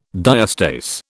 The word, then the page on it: diastase: British English